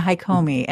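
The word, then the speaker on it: Hakomi: And you had already started seeing a therapist named Taffy, and she was doing something called, how do you pronounce it? Hikomi. Yeah, Hikomi.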